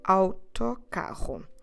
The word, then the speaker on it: autocarro: So autocarro we start the word with the letters AU or in Portuguese AU, and every time you have this diphthong, you should pronounce it as a ow. Ow. So you start with your mouth open, a, and then you close it in order to pronounce the sound u. Ow. Think about the country Australia that in Portuguese we pronounce Australia. And now the word bus that we pronounce autocarro.